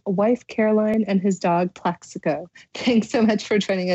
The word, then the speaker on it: Plaxico: I'm most interested in the dog named Plaxico.